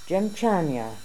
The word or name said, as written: Gemchania